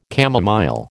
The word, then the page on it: chamomile: American English